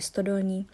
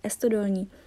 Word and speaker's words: Stodolní: Takže třeba uh, ulice, kterou máme v Ostravě, která se jmenuje Stodolní, nejsou schopní španělští uh, studenti na Erasmu vyslovit a říkají Estodolní.